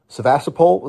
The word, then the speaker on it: Sevastopol: I mean, they did successfully execute strikes on Sevastopol. Is that how you pronounce it? – Yeah.